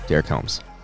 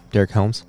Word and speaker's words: Derek Helms: Our guest, as you just heard, Derek Helms. Did I say that right? Derek Helms? – Yes, sir.